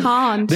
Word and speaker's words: Kant: Because this is actually how you would pronounce it in German. – It's Kant.